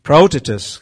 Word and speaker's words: praotetos: It's praotetos. I'm not a Greek person, so praotetos. The accent is on the O.